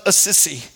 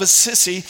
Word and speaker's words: Assisi: In fact, the earliest record that we have of this is from Francis of Assisi. That's actually how you say his name, and I was trying to figure out if he's a sissy or his dad's a sissy, but it's Francis of Assisi, about in 1223.